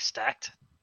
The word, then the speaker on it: stacte: S-T-A-C-E, is that what you said? No. – S-T-A-C-T-E. – T-E. – Like stacked? – Okay. Stacked. – Yep. – Stacked.